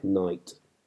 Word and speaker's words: night: And finally, in Knicht, the ch is lost. Even though the orthography still retains it, it is not pronounced, as in words like night.